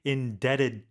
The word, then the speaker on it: indebted: truly indebted to you. And that B is silent, everyone, right? – 맞아요. – Yeah. – 자, 빚이라는 의미로 D-E-B-T, 빚지다 라고 할 때 be indebted to someone.